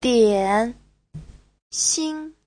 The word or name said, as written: dian3xin5